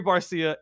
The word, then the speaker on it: Barcia: Now, for like the longest, I kept pronouncing it Cherry Barca or Cherry Barca. And then eventually someone was like, "Cool, it's Cherry Barcia," and it's a play on the dude from, I already forget the band's name, like the wrongful, I don't even remember it.